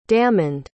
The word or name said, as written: damond